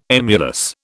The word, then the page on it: emulous: British English